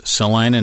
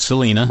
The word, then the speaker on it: Salina: And, of course, we had to help them out to say it's Salina, not Selena.